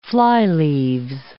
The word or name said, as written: flyleaves